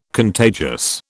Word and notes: contagious: British English

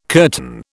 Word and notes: curtain: British English

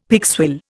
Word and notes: pigswill: Indian

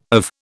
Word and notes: of: British English

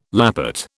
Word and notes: lappet: British English

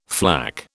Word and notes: flak: British English

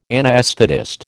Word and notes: anaesthetist: American English

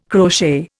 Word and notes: crochet: Indian

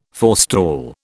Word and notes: forestall: British English

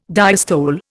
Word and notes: diastole: Indian

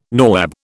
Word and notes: nawab: British English

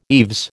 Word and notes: eaves: American English

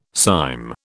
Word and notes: cyme: British English

